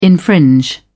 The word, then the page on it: infringe: British English